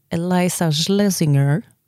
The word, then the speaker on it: Eliza Schlesinger: Det finns en komiker som heter Eliza Schlesinger. Och det uttalas exakt så.